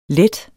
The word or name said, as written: let